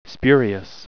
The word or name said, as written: spurious